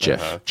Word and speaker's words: GIF: Anyways, he had a presentation in 2013 at the Webby Awards, and he said it is pronounced GIF, J-I-F, not G-I-F.